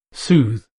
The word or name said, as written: sooth